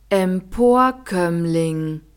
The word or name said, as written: emporkoemmling